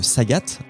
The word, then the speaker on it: Sagat: Si on a joué au jeux vidéo, bon bah tout de suite on reconnaît euh, Ryu, qui se bat contre Sagat. Sagat qui en français est appelé Saga. Alors je sais pas si c'est fait exprès ou pas. On ne prononce alors, pas le T.